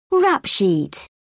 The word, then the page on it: rap sheet: British English